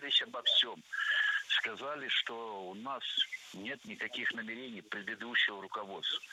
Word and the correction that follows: намерений: Намерения – это неверно. Правильно – намерение, намерений. Ударение в этом слове ставим на второй слог и не меняем это ударение при изменении слова. Намерений, намерениях, намерению. Ну а далее мы разберем ударение в слове, с которым не согласна более половины населения России.